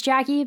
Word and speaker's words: Jacy: So my name is Jackie Corral. My first name is spelled J-A-C-Y. So mm-hmm. when everyone sees J-C, it's Jackie. – It's Jackie, but you know, my parents thought that would be a fun lifelong break.